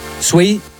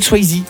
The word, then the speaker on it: Swayze: Toi tu dis Patrick Swayze. – Ah, je dis Patrick Swayze. – Voilà. Ouais, parce que le E c'est I en anglais.